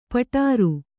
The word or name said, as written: ಪೌಡರು